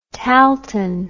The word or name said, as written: tahltan